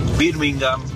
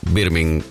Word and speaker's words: Birmingham: Todos los equipos que terminan con ham, uh-huh, H-A-M, bueno, la H es muda. Ejemplo, Tottenham, Birmingham. Creo que la única excepción es West Ham, pero justamente porque se escribe separado.